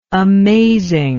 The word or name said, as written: amazingness